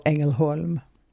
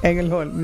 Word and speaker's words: Ängelholm: Southern Sweden, something called Ängelholm. – Sounds beautiful. – Uh-huh. Can you try to pronounce it? – Ängelholm. – Pretty good. – Ängelholm. – Yeah. Ängelholm, yeah.